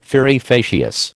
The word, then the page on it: fieri facias: American English